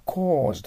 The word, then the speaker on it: cause: 次は、 cause と発音します。 au の時は伸ばします。 o。 cause です。 cause じゃないです。 cause です。 because の cause なんです。